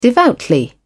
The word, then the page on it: devoutly: British English